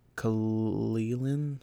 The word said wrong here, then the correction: Cillian: Murphy? I don't know if I said that right. – I have no idea who that is. – The top right. Or left, sorry. – Cillian Murphy?